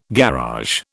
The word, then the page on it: garage: British English